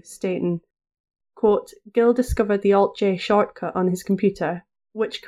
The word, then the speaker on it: Alt-J: This was kind of our band name, but we chose to pronounce it Alt-J, because we knew we were being played on the radio.